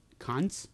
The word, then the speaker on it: Konz: Center out of Wisconsin, Peter Konz. – Spell it. – K-O-N-Z. – Konz. – Konz. So it's like, think like William Shatner in the second Star Trek movie. Konz, right?